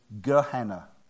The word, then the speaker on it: Gehenna: It's actually in Greek that the way they pronounce that valley is Gehenna.